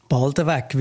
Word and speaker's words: Baldenweg: Ja, also auf Dialekt sagt man eher Baldenweg, wie du das auch gesagt hast, ohne N und mit einem kurzen E im zweiten Teil.